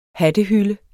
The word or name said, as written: hattehylde